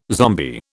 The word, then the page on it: zombie: British English